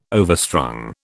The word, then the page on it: overstrung: British English